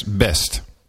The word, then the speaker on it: Best: Bart Bertens is it pronounced Best? Netherlands? – Yes, Best. Correct.